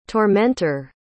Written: tormenter

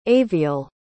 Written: aviel

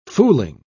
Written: fooling

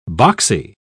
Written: boxy

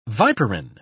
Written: viperine

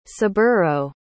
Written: saburo

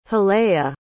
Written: holea

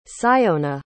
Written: syona

